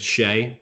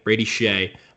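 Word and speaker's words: Brady Skjei: It's spelled Skagey on the back of his jersey, but it's Shea, Brady Shea.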